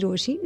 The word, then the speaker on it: Rochie: मेरा नाम है मिर्ची रोशी, नाम की स्पेलिंग है RoCHIE, and I am the girl in love with love.